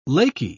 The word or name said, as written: Laky